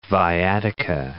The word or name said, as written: viatica